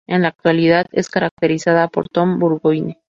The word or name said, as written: actualidad